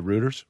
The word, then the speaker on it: Reuters: Yeah, Reuters.